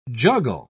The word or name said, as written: juggle